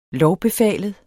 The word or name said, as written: lovbefalet